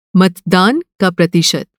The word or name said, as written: मतदान